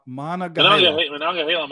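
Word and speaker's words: Monongahela: So you know what, Mahana, Mah- what is it, Mahanagahela? How do you say it, Mahanagahela? – Mahanagahela Mike. There we go.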